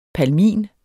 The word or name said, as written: palmin